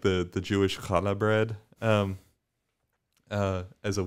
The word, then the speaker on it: challah: Yeah, it looks like challah when you when you read it, but it's challah is how you say it.